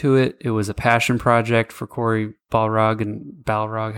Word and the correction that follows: Barlog: however you say his name, Barlog. – The opposite of the giant creature in the... – Yeah, I said Balrog, which is the Lord of the Rings.